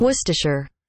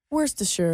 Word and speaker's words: Worcestershire: Worcestershire. – Worcestershire. – So it is, they have it in three different syllables here. Like wuh, then stu, and then shure. – Worcestershire.